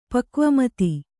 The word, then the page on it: ಪಕ್ವಮತಿ: Kannada